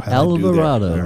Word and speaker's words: Alvarado: Jose Alvarado. – Alvarado, right on the money.